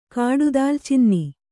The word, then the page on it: ಕಾಡುದಾಲಚಿನ್ನಿ: Kannada